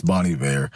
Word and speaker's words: Bon Iver: But we unpacked Exile by Taylor Swift, and I've been told his name is Bon Iver. Really don't think that's the way you say it. And if it's the way he says it, sir, you're saying it wrong. It's Bon Iver.